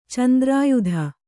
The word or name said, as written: ಚಂದ್ರಾಯುಧ